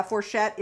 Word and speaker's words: fourchette: So the fourchette is how you say that. Okay. – Yeah, fourchette is the bottom of the inner labia.